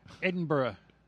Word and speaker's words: Edinburgh: Edinburgh.